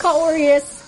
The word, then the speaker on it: Kaorious: It's just K-A-O-R-I-O-U-S. Corius. Everywhere.